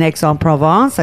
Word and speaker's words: Aix-en-Provence: You worked at Le Clos de la Violette in Aix-en-Provence.